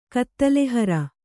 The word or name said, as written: ಕತ್ತಲೆಹರ